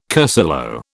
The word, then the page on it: cursillo: British English